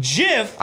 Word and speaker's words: GIF: The dictionary wrote that GIF may be pronounced with either a soft G as in giant or a hard G as in graphic.